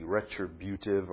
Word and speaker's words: retributive: Yeah, I'm making sure I'm saying that right. You need to know how it's spelled. It's R-E-T-R-I-B-U-T-I-V-E. Retributive or retributive.